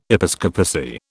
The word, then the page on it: episcopacy: British English